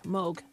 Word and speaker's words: Moog: So I always pronounce that wrong. It's Moog, not Moog. Moog. Okay, got it. – It's Moog. Yeah. Yeah. Rhymes with Rogue.